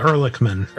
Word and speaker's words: Ehrlichman: You're better at pronouncing this. I studied German for a little while. Ehrlichman.